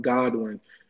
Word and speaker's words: Godwin: It's Tom Godwin. I know some people think it's Goodwin because they think there's a silent O, but it's not.